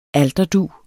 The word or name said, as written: alterdug